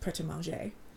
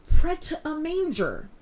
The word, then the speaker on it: Pret-a-Manger: But thanks to Six, I know that it is pronounced Pret-a-Manger. So, thank you, Six. I really appreciate that, because I was having to say, I don't know how to pronounce it, so I'm just going to say it as white as humanly possible. Pret-a-Manger.